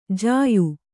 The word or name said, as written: ಜ್ಯಾ